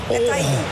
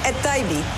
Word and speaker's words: Etaibi: Doe jij zijn naam eens even uitspreken, Fresia? – Etaibi. – Geweldig. – Emin Etaibi. – Dat spreek je helemaal goed uit.